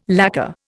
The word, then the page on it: lacker: Indian